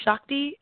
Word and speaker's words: Shakti: So, again, my name is Andre Shakti. It's spelled A-N-D-R-E. My last name is S-H-A-K-T-I.